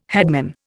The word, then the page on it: headman: Indian